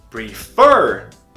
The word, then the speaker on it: prefer: Como, por exemplo, o verbo prefer. Prefer termina com F consoante, E vogal, R consoante. Termina em CVC e a tônica é no fur, que é na última sílaba.